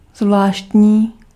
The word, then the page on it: zvláštní: /ˈzvlaːʃtɲiː/